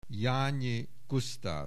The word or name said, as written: Jany Gusztav